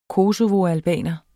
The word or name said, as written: kosovoalbaner